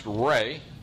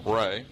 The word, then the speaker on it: Re: And sometimes you're going to see his name spelled R-E, pronounced Ray. If you have trouble remembering that, think of a sun, Ray.